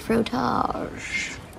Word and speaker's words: frottage: But for the record, it's pronounced frottage.